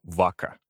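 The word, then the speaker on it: vacca: В основе этого слова лежит латинское «корова», и произносится оно «вака».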